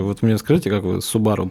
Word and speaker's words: Субару: Это даже никто не поправляет, потому что ну, никто, ни, никто не говорит правильно Субару, как бы, потому что там надо на последний слог ударение Субару.